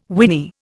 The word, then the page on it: whinny: Indian